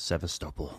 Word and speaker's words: Sevastopol: Sevastopol. I don't know if I say that correctly. – That's right. Sevastopol.